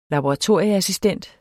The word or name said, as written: laboratorieassistent